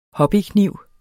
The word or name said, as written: hobbykniv